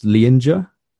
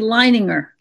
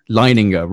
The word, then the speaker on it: Leininger: And you include in your book an extraordinary case involving a young boy named, and I might pronounce his second name incorrectly here, James Leininger? – Actually, Leininger. – Leininger, right.